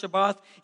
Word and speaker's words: Shabbat: Also, it's pronounced Shabbat.